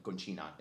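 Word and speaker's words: Concina: DJ Roberto Conchina, Conchina is the right pronunciation.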